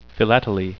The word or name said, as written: philately